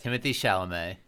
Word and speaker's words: Timothée Chalamet: It's Timothée Chalamet.